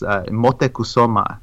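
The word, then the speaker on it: Motecuhzoma: Though I always like to cite that there was actually a previous Moctezuma, Moctezuma the First, who was actually, and I think it's actually in Nahuatl, it's Motecuzoma. It would be the more closer pronunciation.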